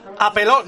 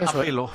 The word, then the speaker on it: apelo: Apeló... No, no lleva tilde. Es apelo. Apelo.